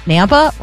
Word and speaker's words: Nampa: Nampa rhymes with Tampa.